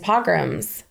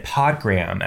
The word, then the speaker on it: pogrom: Because of refugees coming from Eastern Europe after all these pogroms had started. So, after all these what? – Pogroms. Fact checker, am I saying this correctly? – It's a pogrom.